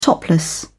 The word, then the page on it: topless: British English